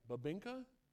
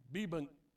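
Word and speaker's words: bibingka: Babinka? – Babinka. – Bibinka.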